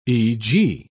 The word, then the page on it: e.g.: British English